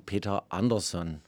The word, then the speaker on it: Peter Andersson: Äh, das sind ja keine Amerikaner, sondern Schweden und deswegen Peter Andersson.